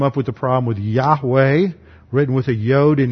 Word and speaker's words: Yahweh: And they would still pronounce that Yahweh, and then it came into English, and we pronounce it Jahweh with a G, and then it became Jehovah.